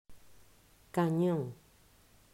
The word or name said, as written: Canon